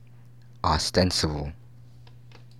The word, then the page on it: ostensible: American English